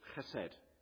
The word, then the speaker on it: chesed: The word is chesed. Chesed. If you want to spell that, it's transliterated, it's C-H-E-S-E-D.